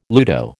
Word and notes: ludo: American English

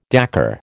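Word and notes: décor: American English

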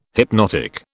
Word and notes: hypnotic: British English

